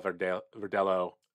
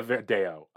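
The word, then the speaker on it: Verdelho: Sorry, I have the Verdello, okay. Rather. I always want to say it like it's Spanish and it's actually Portuguese.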